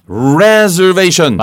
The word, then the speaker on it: reservation: Reservation. – 맞습니다. Reserve의 명사형이라 발음이 reservation이 아니고. – Oh, that's right. Re 네. becomes re. – 맞습니다. – So reserve, reservation.